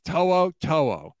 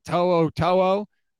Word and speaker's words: To'o To'o: That's how you say it. To'o To'o.